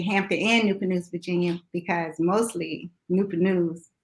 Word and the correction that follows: Newport News: And how did you pronounce that? – Newport News. – It's Newport. I always say Newport News. – Yeah, Newport News. – It's Newport. – Hey. – It's Newport News, y'all. I've been saying it wrong all this time.